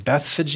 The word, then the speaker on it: Bethphage: And going on, they're coming into this city of Bethphage. I've heard people pronounce it Bethphage. You can do what you want with it.